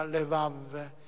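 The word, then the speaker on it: levav (לבב): Vi ricordate che in ebraico la parola cuore si dice in tuo modi leb, si pronuncia lev, e lebab, che si pronuncia levav.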